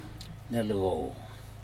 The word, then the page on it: nelco: Aneityum